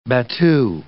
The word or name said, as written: battu